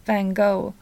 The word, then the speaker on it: van Gogh: Ska vi uttala honom så som han uttalas på nederländska? – Ja, vi kan försöka. För det är väl jätteklurigt, eller? – Det är det, men vi kanske kan lära oss ja. Då uttalas han ju van Gogh. Van Gogh.